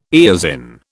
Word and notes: eosin: British English

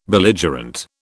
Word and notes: Belligerent: British English

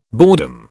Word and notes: boredom: British English

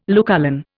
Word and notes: Lucullan: Indian

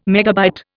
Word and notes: MB: Indian

